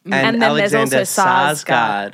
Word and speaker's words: Sarsgaard: Well, they're two different names. – Skarsgård. – Yeah, I think it's Sarsgaard. – He is a Sarsgaard. – Yeah. – Alexander is a Skarsgård.